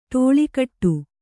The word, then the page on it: ಟೋಲುಗೇಟು: ṭōlu gēṭu